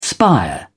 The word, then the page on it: spire: British English